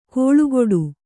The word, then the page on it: ಕೊಳುಕೊಡು: koḷu koḍu